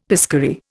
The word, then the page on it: piscary: Indian